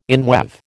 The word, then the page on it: inweave: American English